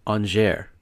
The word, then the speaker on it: Angers: I actually have to look up how to pronounce this. It's Angers.